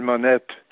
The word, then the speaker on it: Monette: un Canadien et je m'appelle Monette. Les, euh, Canadiens français oh. euh, prononcent plutôt les consonnes finales dans les noms propres.